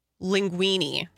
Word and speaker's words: linguine: Linguine. That's how you say it.